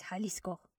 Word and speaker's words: Jalisco: And the pronunciation you were looking for is Jalisco, Mexico.